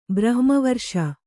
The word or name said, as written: ಬ್ರಹ್ಮವರ್ಷ